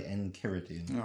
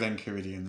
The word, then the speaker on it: Enchiridion: It's derived from the Greek, so I'm going to say Enchiridion. – All right. Let's go with Enchiridion then.